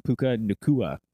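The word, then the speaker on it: Puka Nacua: Puka Nuka. – Oh, shit. Okay. – I'm saying that wrong. Puka Nakua. – Puka Nakua. – There it is.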